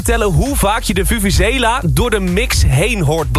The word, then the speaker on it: vuvuzela: Vuvuzela. Ja, zo spreek je het uit volgens mij.